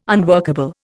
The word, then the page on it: unworkable: Indian